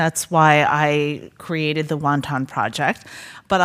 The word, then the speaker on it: wonton: We call wonton soup wonton because the first immigrants that came here to the United States were Cantonese, and they pronounce that dumpling as wonton.